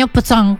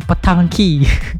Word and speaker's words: Petanque: Namanya Petang Temu Pro 2. Tulisannya PETANQUE. Tadi hampir aja bacanya petangki.